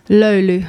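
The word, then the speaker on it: Löyly: How do you pronounce it again properly? – Lolu. – Lolu. Okay, it's spelled L-O with an umlaut, Y-L-Y.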